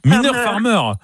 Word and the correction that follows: Mylène Farmer: Je prononce mal. – Alors, prononcez-le moi bien, sinon je ne peux pas l'accorder. – Mylène Farmer.